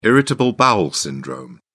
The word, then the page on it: irritable bowel syndrome: British English